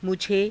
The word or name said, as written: mujhe